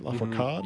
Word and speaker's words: Lafourcade: From Natalia Lafourcade, is that right? Lafourcade? – Mm-hmm, yeah.